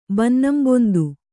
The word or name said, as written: ಬನ್ನಂಬೊಂದು